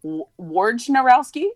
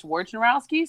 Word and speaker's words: Wojnarowski: That's not actually how you say his name. – How do you say his name? – It's actually like Wojnarowski. It's like, oh, really? – It's like totally not correct. But, like, nobody knows that it's Wojnarowski, so he just goes by Woj.